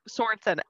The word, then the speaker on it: Sorensen: Like my, like Sorensen, S-O-R-E-N-S-E-N.